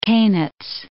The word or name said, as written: Koniz